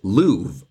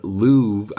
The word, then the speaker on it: love: One of my students, actually a few years ago, did some research and found that the way they pronounced love back in the day was as louve. And so back in Shakespeare's time, louve, I louve you, and remove would then rhyme with each other,